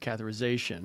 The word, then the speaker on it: catheterization: Okay, so for those folks that are watching who don't know what a heart cath is or heart catheterization, am I saying that right? – Yeah.